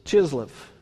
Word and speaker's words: Chislev: We also know that the month is Chislev, and it's pronounced with a CH there.